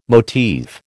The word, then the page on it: motif: American English